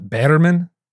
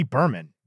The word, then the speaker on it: Berman: Not Batterman? – No, I think it's Judy Berman.